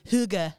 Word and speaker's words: Hygge: Hygge. – The Hygge game.